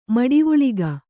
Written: ಮಡಿವುಳಿಗ

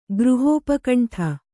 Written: ಗೃಹೋಪಕಂಠ

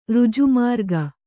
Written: ಋಜುಮಾರ್ಗ

